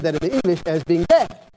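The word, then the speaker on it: bad (בד): In Hebrew, it's spelled B-A-D, bad.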